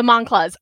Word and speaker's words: Moncla: I mispronounced Preston's last name. And the thing is, I met the Monclas. I met them, like all of them at Houston. And his last name is pronounced Moncla, and it's the Pinball Mafia.